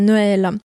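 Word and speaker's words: Noël: И написание, да, там над буквой «э» пишется акцент «трема», две точечки такие, произносится Нуэля.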